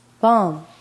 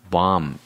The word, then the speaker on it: balm: Bomb. Bomb. – 향유, 위안물. 향유라는 것은 향기로운 기름이란 뜻이에요. 그러니까 잘 기억이 나지 않으니까 L은 안 읽어요.